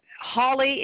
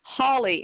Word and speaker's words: Holly: Hawley is H-O-L-L-Y. – And what's your first and last name? – Sylvia, S-Y-L-V-I-A, Hawley, H-O-L-L-Y.